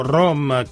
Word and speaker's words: Rhom: Solo che si scrive R-H-O-M, quindi R per Roma, home è la casa.